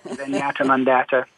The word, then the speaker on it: Zenyatta Mondatta: I grew up in the 80s listening to my Sony Walkman and, yep, Zenyatta Mondatta.